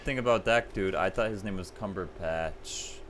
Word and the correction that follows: Cumberbatch: That's what I thought, too. – Like a cucumber patch. – Benedict Cucumber Patch. – Wait, have you ever seen the videos of him pronouncing penguin? – No. – So funny. – How does he say it? – He says it as Ponguin? – Ponguin. Oh, what? His name's Cumberbatch?